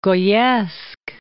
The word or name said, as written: Goyesque